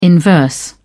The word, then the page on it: inverse: British English